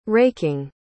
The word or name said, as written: raking